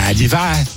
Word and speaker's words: Liévin: Comment on dit? Si on veut bien le prononcer, c'est donc. – Liévin, Bilou Liévin.